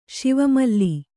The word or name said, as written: ಶಿವಮಲ್ಲಿ